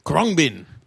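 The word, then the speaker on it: Khruangbin: Krungbin.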